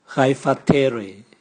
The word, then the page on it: gaivatere: Raga